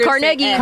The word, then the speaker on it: Carnegie: But I got here and they're like, you better pronounce it Carnegie.